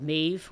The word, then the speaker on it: Medb: But the first book out is called Maeve, which is spelled M-E-D-B. It's an Irish name, of course.